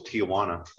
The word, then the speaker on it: Tijuana: I just said it was Tijuana. – That's what I always said. – That's what everybody I've ever heard say it has always said. – All right, I just meet the main drag Tijuana.